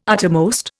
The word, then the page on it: uttermost: Indian